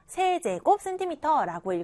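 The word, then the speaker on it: cm³: 이 정육면체의 부피를 1세제곱 센티미터다라고 쓰고 1세제곱 센티미터라고 읽어줍니다. 우리가 쓸 때에는요, 센티미터 위에다가 자그만하게, 조그만하게 3이라는 숫자를 썼어요. 그래서 세제곱 센티미터라고 읽을 수 있었던 거죠.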